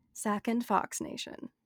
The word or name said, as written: Sac & Fox